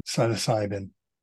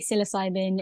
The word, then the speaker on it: psilocybin: So how did you get so interested in psilocybin? Is that how you pronounce it? – In America, they say psilocybin. In the UK, they say psilocybin, but I tend to say psilocybin.